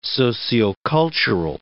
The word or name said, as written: sociocultural